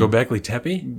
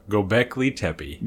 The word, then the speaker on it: Göbekli Tepe: Göbekli Tepe? – Yes, that one. Göbekli Tepe. Say it one more time. – Göbekli Tepe. – Göbekli Tepe. – Yes. That's it.